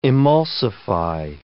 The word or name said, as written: emulsify